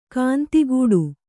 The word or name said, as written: ಗಂಧಕುಟಿ